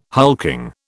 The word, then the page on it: hulking: British English